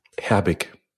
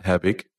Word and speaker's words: Herbig: But the thing is, I have a dispute even with Germans, because most Germans say Herbig, and like I try to insist on Herbig, so it's super complicated how you pronounce the G.